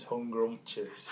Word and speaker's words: Hungrumptious: You say that if you imagine hungry and scrumptious joined together, it becomes hungrumptious.